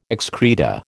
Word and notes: excreta: American English

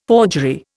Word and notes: forgery: Indian

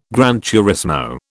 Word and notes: gran turismo: British English

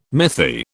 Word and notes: mythi: British English

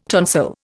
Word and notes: tonsil: Indian

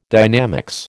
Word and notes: dynamics: American English

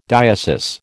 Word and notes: diocese: American English

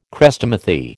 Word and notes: Chrestomathy: American English

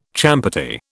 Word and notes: champerty: British English

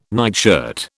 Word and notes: nightshirt: British English

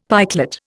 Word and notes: pikelet: Indian

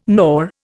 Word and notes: gnaw: Indian

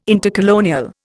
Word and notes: intercolonial: Indian

